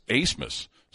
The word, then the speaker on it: Asmus: And it's spelled A-B-M-A-S, but it's pronounced Asmus.